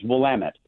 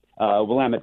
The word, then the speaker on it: Willamette: Yeah, we're right on the, but I got to correct the pronunciation, it's Willamette, Willamette Valley.